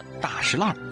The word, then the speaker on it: 大栅栏: 差别最大的当属大栅栏，这个栅栏啊会读作石栏，也叫做大石栏。